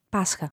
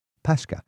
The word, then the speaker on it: Pascha: First of all, how do you say Easter in Greek? – Pascha. – Pascha.